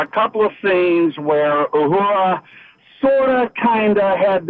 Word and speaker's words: Uhura: Uhura, but yes. – Yes, Uhura. How do you pronounce it? Ohura? – Uhura.